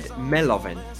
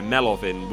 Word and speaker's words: MELOVIN: I would have just said Melovin. – Yeah, Melovin would make more sense, given he is Ukrainian.